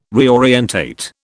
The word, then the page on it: reorientate: British English